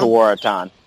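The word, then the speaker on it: Tewaaraton: And you were a finalist for tell me if I pronounce this right, by the way. Tuaratan Award? Is that how you Correct. – Pronounce it? – Correct. Tuaratan.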